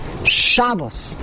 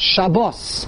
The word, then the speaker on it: Shabbos: In Yiddish, every single word, the emphasis is on the beginning. Therefore, it's Shabbos, not Shabbos.